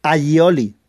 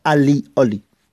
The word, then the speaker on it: allioli: Por eso se escribe A-L-L-I-O-L-I, Allioli. Pero se pronuncia Allioli, no Alioli.